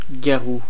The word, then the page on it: garou: /ɡa.ʁu/; France